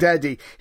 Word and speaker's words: Baghdadi: The ISIL leader, Abu Bakr al-Baghdadi. No, I'm saying that wrong. Baghdadi. He was the leader, I'm sure, still. – Like Baghdad. – Baghdadi.